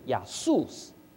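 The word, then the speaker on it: 亚 素 斯 (Ἰησοῦς): 耶是耶和华的缩写，耶和华的希伯来文读音是亚卫，耶稣的希腊文读音是亚素斯，所以耶是耶和华的缩写。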